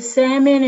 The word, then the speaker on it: salmon: And I wanna make note of the fact that you did not pronounce the L in salmon, which is a point of contention for a lot of people in the world today.